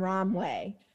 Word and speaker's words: Romwe: Did you know, I'm sorry, this is a tangent, but I recently saw an ad on TikTok for what I thought was called Romwe, but it's the like voiceover pronounce person rhymed it with like, it's gonna be a good day because I'm gonna order from Romwe.